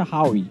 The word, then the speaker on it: Huawei: Eu, eu, se eu não me engano, se pronuncia Huawei.